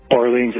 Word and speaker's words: Barlean's: Barleen's is spelled B-A-R-L-E-A-N-S, and the product is Key Lime Swirl.